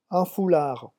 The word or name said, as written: un foulard